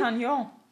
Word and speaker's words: D'Artagnan: D'Artagnan, also es wird geschrieben. – D'Artagnan. – Wird es D'Artagnan? Genau, der ist französisch jetzt wahrscheinlich, ja. Gell? Wegen der Figur wahrscheinlich. Ja. Von den drei Musketieren. D'Artagnan, können Sie natürlich auch nennen.